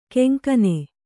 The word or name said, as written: ಕಂಗನೆ